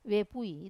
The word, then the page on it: vepui: Wamesa